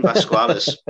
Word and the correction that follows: Velasquez: I hope I said that right. – Although I much prefer that pronunciation. – No? Ah. – Velasquez. – How do you pronounce it? Velasquez. Kane Velasquez.